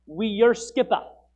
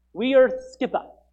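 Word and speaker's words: weorþscipe: But it's pronounced like this. Wierskippa. Wierskippa.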